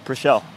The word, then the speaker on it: Preschel: You say it like that, Preschel? – Preschel. – Preschel, P-R-E-S-C-H-E-L.